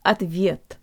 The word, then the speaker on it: ответ: guys, today you'll learn how to pronounce the word ответ, or answer. The O sounds like a schwa.